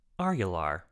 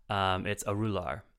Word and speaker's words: Arular: I have been pronouncing Arular. Not correct. – What is it? – Not super far off either, but it's Arular. Ah, and it is her father's name.